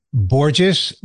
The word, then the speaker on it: Borges: It is pronounced Borges, rhymes with gorgeous, like my wife and my daughter.